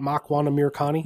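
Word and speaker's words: Makwan Amirkhani: Makwan Amir Khani? – Yes, nailed it.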